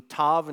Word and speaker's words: Tav: In your Bible translation, there's probably a heading and it says T-A-W, but the way that you pronounce it in Hebrew would be Tav.